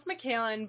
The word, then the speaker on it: Mikalyn: So it's pronounced Mikalyn, but it's okay. I get a lot of pronunciations.